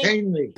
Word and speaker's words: Kahnle: Kahnle.